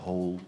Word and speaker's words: Hohl: y, un, y otro gran amigo yankee que tocaba el bajo, que en realidad eh, es un gran guitarrista llamado Rod Hole, como agujero, pero se escribe H-O-H-L.